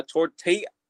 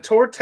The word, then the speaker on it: torte: A torte, T-O-R-T-E. – Is it a torte or is it a tort? – It probably is a tort.